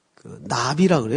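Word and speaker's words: 나비 (נביא): 그런데, 선지자 하면 이제, 나비라 그래요, 나비. 발음이 나비, 히브리 말로. 나비야, 나비야, 이리 날라오너라. 그 발음하고 아주 똑같아요.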